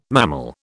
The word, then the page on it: mammal: British English